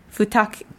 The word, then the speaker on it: Futaki: How about Futaki? Futaki. – Sounds right.